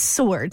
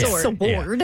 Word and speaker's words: sword: Well, no, you can pull it up right now, and it's S-O-R-D is how you pronounce it. It's sword, not sword.